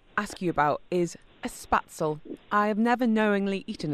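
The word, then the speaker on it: Spätzle: So a spatzel, there's a sh in there.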